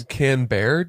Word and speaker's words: Canberra: It's spelled C-A-N, but since they rhymed, I figured that would still be a nice... – Canberra, did you say? – Canberra. – Oh, okay. Yeah. All right. Cool. – Canberra.